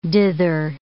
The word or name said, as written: dither